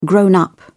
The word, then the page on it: grown up: British English